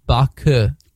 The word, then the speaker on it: Baku: Actually, well, it's pronounced Baku if you speak the language, which I don't, but...